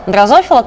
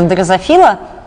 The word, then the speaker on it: дрозофила: Ну, после того, как я сказала дрозофила, как у нас говорили обычно на факультете, и была сотня комментариев о том, что говорить надо дрозофила, в Википедии, правда, написано дрозофила, но же организм, с которым я знакома и сталкивалась на протяжении 12 лет своей жизни, все-таки дрозофила. И мне кажется, что, в общем, имею право на такое произношение, если нас много.